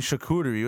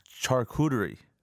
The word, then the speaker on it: charcuterie: And you're not even saying it right, okay? – There you go. – You're saying charcuterie. What the fuck is that? All right? – How do you say it right? – It's charcuterie.